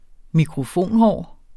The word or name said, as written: mikrofonhår